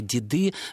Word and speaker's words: деды: Значит, литературной формой считается форма деды, то есть ударение сохраняет свое постоянное место.